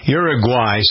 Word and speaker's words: Uruguay: Uruguay, say it again. Uruguay, some people pronounce it Uruguay.